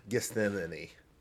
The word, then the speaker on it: Gethsemane: Gethsemane.